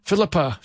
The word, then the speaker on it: Philippa: Now here's Philippa Hanna, or sorry, Philippa, I just learned how to say her name.